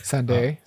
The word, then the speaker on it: Sandé: This song was co-written by Emily Sandy, or Emily Sandé, I'm not sure how to pronounce it. – Sandé.